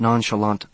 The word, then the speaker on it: nonchalant: She pronounced nonchalant the French way, without sounding the T.